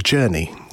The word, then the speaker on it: JRNI: Glenn, you are the chief architect now of a company called Journey. – Yep. – Which is spelled J-R-N-I. – Yeah, which took us a while to get the right spelling and the right pronunciation, but it's good.